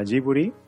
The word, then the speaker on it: Jiburi: Vale, eh, mi podcast se llama Jiburi, que se escribe con J y con, B.